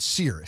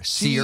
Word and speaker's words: CEIR: I pronounce it SEER. SEER, okay. C-E-I-R, which stands for the Center for Election Innovation and Research.